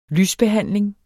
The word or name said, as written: lysbehandling